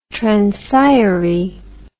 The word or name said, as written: transire